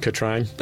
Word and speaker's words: Katrin: Katrin, K-A-T-R-I-N. That's a weird name. – It's pronounced Katrine.